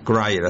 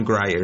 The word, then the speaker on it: Grier: Grier, I think is the right pronunciation. Pam Grier is the right pronunciation of her last name.